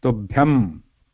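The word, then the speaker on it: Tubhyam (तुभ्यम्): With the M consonant at the end. Tubhyam. And this is not Anuswara. Remember? Ma sound is not Anuswara. This is a half ma.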